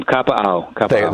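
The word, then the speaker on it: Kapa'au (Kapaʻau): And is it Kapa'ua, Hawaii? I'm sure I'm mispronouncing that. – Close, Tom. Kapa'au.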